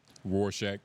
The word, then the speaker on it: Rorschach: Rorschach.